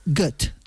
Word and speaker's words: Goethe: Y según he visto, se pronunciaría Goethe. – Mira, bueno, como Gotha.